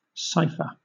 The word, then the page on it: cipher: British English